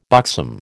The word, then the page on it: buxom: American English